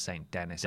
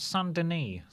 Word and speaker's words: Saint-Denis: I know it's not pronounced St. Denis. I was being facetious in the stream and people were saying, I think it's pronounced St. Saint-Denis.